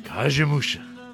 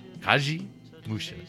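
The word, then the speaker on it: Kagemusha: How do you say that again? – Kajimusha. – Oh, okay. – Kajimusha.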